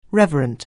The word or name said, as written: reverent